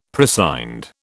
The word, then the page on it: prescind: British English